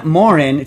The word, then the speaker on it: Morin: Aaron Morin, it looks like it's pronounced Morant, but they said Morin, from Edmonton, Alberta, writes in, "Dear Zappos lover and rap hater."